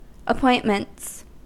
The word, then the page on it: appointments: American English